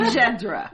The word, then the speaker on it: Mjadara: Mjadara. – Mjadara. – Mjadara. – Mjadara. You start with the M and you jump right to J.